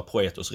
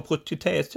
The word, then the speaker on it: Proethos: Och det är ingen som uttalar Proetos rätt första gången. Utan jag har säljare som ringer mig och så säger de, Hej, är du eh, VD för Protetes?